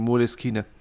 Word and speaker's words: Moleskine: Äh, und, und ähm, das ist eine italienische Marke äh, und da wird ja alles ausgesprochen, wie es geschrieben wird. Deswegen Moleskine.